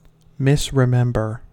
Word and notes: misremember: American English

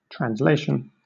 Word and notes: translation: British English

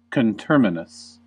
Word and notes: coterminous: American English

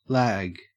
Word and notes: lag: Australian English

